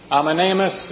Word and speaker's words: Amenemhat: We come across a rather interesting fellow, Amenemhat in the Egyptian. Amenemhat, if you want to use the Greek spelling and use a more Egyptian pronunciation, or Amenemhes if you happen to be Greek, which I'm not, so it'll be Amenemhat the Third, who was king of Egypt then from 1843 to 1797.